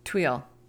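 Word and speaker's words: tuile: Tweel.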